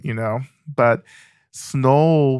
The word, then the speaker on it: SNOELL: What is S-N-O-E-L-L? – So Snow, sometimes pronounced Snowell, but Snowell is only pronounced during the holidays. But those are the two primary pronunciations, but Snowell is just my method of working with sensation and material in my mind and in my body, right?